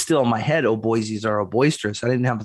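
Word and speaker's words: O'Boisies: O-B-O-Y? – O apostrophe B O I S I E S, like Boise, Idaho. – I see. Oh, Boise's.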